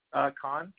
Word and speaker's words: Khan: My first name, yeah, it's B-I-L-A-L. – Bilal? – Yes, sir. – All right. Last name? – Khan, K-H-A-N. – I have B-I-L-A-L-K-H-A-N.